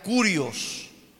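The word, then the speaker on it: curios (κύριος): Esta palabra señores viene de la palabra griega también señores, que significa, mis hermanos, se pronuncia curios, curios.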